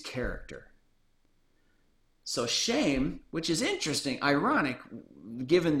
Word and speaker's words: Shem: Now Shem is Strong's Concordance number 8035, and his name is pronounced Shame. Now 8035 basically just says he's a son of Noach.